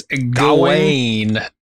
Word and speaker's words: Gawain: Garwin? I always pronounce this Gawain. – Gawain, I always press Gawain but too, but it's Gawain. Gawain.